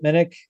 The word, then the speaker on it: Minnich: I can be found on Twitter at Chip Minnick. Last name is spelled M-I-N-N-I-C-H.